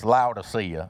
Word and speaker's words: Laodicea: And forgive me, I'm going to say a lot of like Greek words today, and I'm going to pronounce them like I grew up in Georgia because that's how I pronounce them, okay? So it's Laodicea, okay?